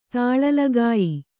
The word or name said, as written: ತುಳಿಲ್ಗೆಯ್